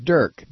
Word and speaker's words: Deryk: I know it's spelled Derek, but I pronounce it Dirk because she spelled it when he was born, D-E-R-Y-K. That looks like Dirk to me.